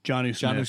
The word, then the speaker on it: Jonnu: Nelson Aguilar, Kendrick Bourne, Hunter Henry, Jonnu, is that how you say it? – Jonnu Smith.